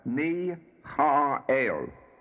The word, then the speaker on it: Michael: The archangel is Michael, but pronounced as Mi-ha-el.